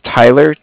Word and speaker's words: Tyler: My name is Tyler, T-Y-L-E-R. I'm calling with Cardiff. It's spelled C-A-R-D-I-F-F, as in Frank.